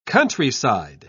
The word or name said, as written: countryside